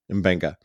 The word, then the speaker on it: Mbenga: like the relationship between Pike and Mbenga. Is that how you pronounce his name? – Mbenga.